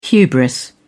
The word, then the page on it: hubris: British English